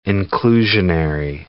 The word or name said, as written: inclusionary